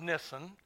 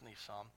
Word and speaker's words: Nisan: And it came to pass in the month of Nisan, and by the way, if it had two S's, it'd be Nissan, wouldn't it? But I think it may be pronounced Nissan.